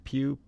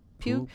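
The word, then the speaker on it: Pugh: How do you say her last name? Puke. – Pew. – Pew.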